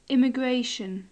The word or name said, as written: immigration